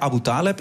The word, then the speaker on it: Aboutaleb: Naast me zit eh, Jasmine Abu Taleb.